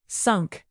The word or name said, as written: sunk